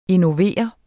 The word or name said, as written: innovere